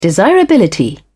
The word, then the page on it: desirability: British English